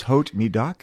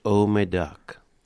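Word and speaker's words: Haut-Médoc: In French, you don't pronounce the T. – I thought the H was always silent. – It is. So you just say, oh, my duck.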